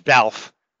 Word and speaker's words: Balfe: Actually, it's Balfe. Rhyme with Ralph.